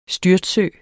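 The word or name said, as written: STYRTSØEN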